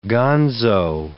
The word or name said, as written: gonzo